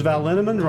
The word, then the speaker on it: Linneman: The equally mysterious Tracy Z, Aviva Lael, Alicia Lemus, Rebecca Hughes, Val Linneman, Rhymes with Cinnamon,